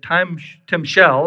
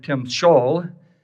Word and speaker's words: timshel: John Steinbeck, back in East of Eden, said that timshel, or some pronounce it timshol, is man's ability to choose between good and evil.